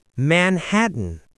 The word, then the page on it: Manhattan: American English